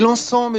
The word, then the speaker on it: l'encens: Et l'encens, me dites-vous? Oui, l'encens ou l'encens, car on doit pouvoir prononcer le S.